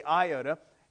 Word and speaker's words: iota: The iota, that's the proper way of pronouncing it, we say iota, is the smallest letter in the Greek alphabet.